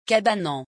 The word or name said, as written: cabanon